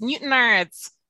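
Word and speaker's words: Newtownards: So, for example, Newtown Ardies in County Down. I'm probably butchered that. – Newton. – Name. – I'm going to correct you on that. It's Newton Ardies.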